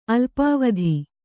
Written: ಅಲ್ಪಾವಧಿ

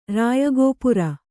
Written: ರಾಯಗೋಪುರ

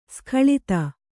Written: ಸೂಕಳತೆ

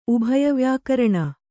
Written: ಉಭಯವ್ಯಾಕರಣ

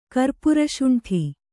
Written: ಕರ್ಪುರಶುಂಠಿ

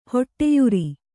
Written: ಹಡಿಯರ

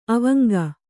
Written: ಅವಂಗ

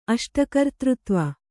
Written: ಅಷ್ಟಕರ್ತೃತ್ವ

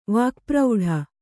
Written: ವಾಕ್ಪ್ರೌಢ

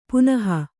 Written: ಪಣಹ

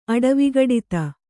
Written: ಅಡವಿಗಡಿತ

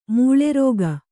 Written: ಮೇಲಾರೈಕೆ

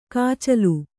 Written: ಕೊಚ್ಚಲು

